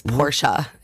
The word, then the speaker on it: Porsche: So Porsche, you know, the car that people like that is gorgeous and fast and all of those things is two syllables.